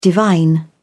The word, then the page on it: divine: British English